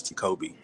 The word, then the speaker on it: Tecoby: So that's not, it's Tecoby, as if you said my name wrong.